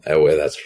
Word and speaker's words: Ewe: Ewe, that's spelled E-W-E.